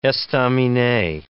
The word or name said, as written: estaminet